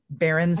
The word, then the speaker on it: Berenstain: It's actually spelled S-T-A-I-N, Berenstain. – I feel like I've seen the internet go wild when people realize that it's not Berenstain Bears, but everybody called, but yeah, that it is spelled with the S-T-A-I-N.